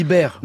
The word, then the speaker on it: Baer: En effet, tant Madame Bechtel que Monsieur Baer, oui, Baer, vous me regardez avec Oui, on dit Baer. – non, que je sache en français, A suivi de E, ça se prononce pas E.